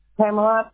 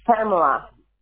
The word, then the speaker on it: Pamela: Pamela. with an A at the end.